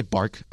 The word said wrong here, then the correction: Bach: I meant Bach.